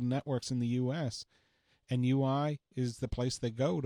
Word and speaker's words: Huawei: How do you pronounce it? UI? – I say Huawei. – Huawei. – But it could be UI.